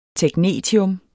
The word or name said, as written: technetium